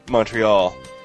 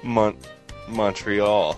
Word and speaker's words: Montreal: This is good job, Montreal. Montreal.